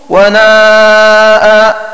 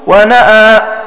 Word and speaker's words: وناء: وقرأ ابن ذكوان وناء بجانبه، هنا وفي فصلت بتأخير الهمزة عن الألف فيصير النطق وناء مثل وجاء. وقرأ غيره ونأى بجعل الهمزة في موضعها مقدمة على الألف.